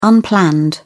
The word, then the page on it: unplanned: British English